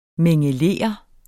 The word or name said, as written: mingelere